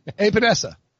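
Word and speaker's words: Epenesa: AJ, I can't ever say it right. How's say it again for me? – Right. Sound it out. – Epinesa. – Yeah, that's good. – Okay, AJ Epinesa.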